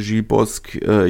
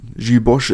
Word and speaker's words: Gilles Bosch: Entsprechend die halbe Startformation schon aussortiert und vor dem Absprung Gilles Bosch unter anderem, beziehungsweise Gilles Bosque, je nachdem, wie man es aussprechen möchte. Gilles Bosch ist korrekt, oder hat er mir persönlich so bestätigt, dass Gilles Bosch korrekt ist. Allerdings muss man auch sagen, dass Bosch immer noch ein abwertender Begriff für sogenannte Deutsche ist. Entsprechend bevorzugen viele Franzosen die Aussprache Bosque.